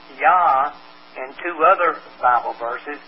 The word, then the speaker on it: Jah: Now, you'll see it written in your Bible in the King James as Jah, J-A-H. However, it's pronounced Yah. Since there's no J sound in the Hebrew whatsoever, and the New King James Bible restores the correct rendering that I've got here, Yah, Y-A-H.